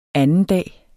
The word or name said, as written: andendag